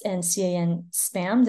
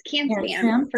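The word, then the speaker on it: CAN-SPAM: and CAN spam. Did I say that right? – Yes, CAN spam for Canada.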